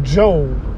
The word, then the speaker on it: Job: Now, this Sunday morning word, we're going to be talking about Job. It's spelled like job for those who are Bible thumpers, but don't really know the Bible like that.